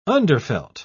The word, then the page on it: underfelt: British English